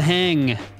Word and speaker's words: Hang: Just says the hang, German pronunciation hang, plural form hang, is a musical instrument in the idiophone class created by Felix Rohner and Sabina Schara in Bern, Switzerland.